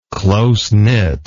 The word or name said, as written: close-knit